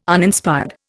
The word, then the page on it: Uninspired: Indian